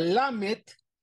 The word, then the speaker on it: lamed: La segunda letra, la lamet, se pronuncia en la cavidad bucal intermedia. Lamet, lamet. No es el labio, es la boca interna, con la mitad de la lengua.